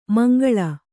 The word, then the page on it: ಮುಂಗಾಳು: mungāḷu